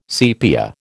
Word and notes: sepia: American English

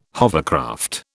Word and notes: hovercraft: British English

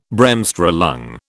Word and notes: Bremsstrahlung: British English

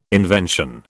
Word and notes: invention: British English

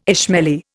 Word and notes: Ismaili: Indian

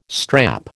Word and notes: strap: American English

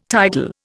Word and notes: tidal: Indian

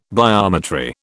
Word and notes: biometry: British English

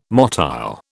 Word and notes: motile: British English

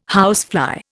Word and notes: housefly: Indian

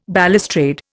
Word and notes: balustrade: Indian

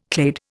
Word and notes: clade: Indian

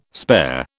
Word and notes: spare: British English

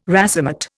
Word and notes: racemate: Indian

